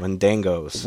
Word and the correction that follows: Wendigos: Wendigos. – Wendigos. Wendangos. Wendigos. – I think they pronounce it Wendigos in the No, you're correct. I said it wrong.